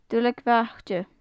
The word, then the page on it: duilleag bhaite: gaelic